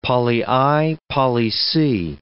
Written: poly I poly C

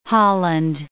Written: Holland

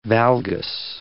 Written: valgus

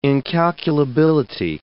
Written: incalculability

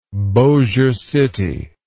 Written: Bossier City

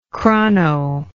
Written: chrono

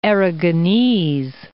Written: Aragonese

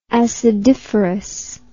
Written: acidiferous

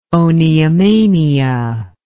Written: oniomania

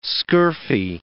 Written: scurfy

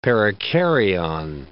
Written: perikaryon

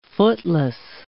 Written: footless

